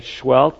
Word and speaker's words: Seheult: Dr. Schwelt, he spells his name S-E-H-E-U-L-T. I don't know how you pronounce that, Schwelt, but that's how it's pronounced. S-E-H-E-U-L-T.